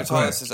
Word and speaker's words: autolysis: Yeah, autolysis. Okay, that's how you that's how you pronounce it.